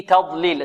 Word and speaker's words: تضليل: في تضليل، التاء هذه ماذا؟ مرققة والضاد مفخمة.